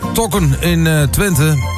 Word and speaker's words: Tokken in Twente: Zeg ik het goed? Tokken in Twente? – Zeker, ik heb niks te corrigeren.